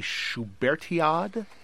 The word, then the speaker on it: Schubertiad: This is called a Schubertiad. Am I saying that right? – Yes, the Schubertiad is an all Schubert concert, and the Schubertiad itself dates from the time of Schubert.